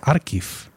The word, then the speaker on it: Archiv: Sería Archiv, ¿no? Eh, ¿En alemán? ¿Cómo lo diríamos? – En, alemán, en alemán sería Archiv.